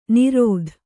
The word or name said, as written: ನಿರತ